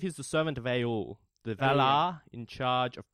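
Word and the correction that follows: Aulë: I think it's pronounced Ale.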